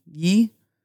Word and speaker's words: ghee: I'm curious too, where you all are getting into some dairy products like the butter and, is it ghee? Ghee?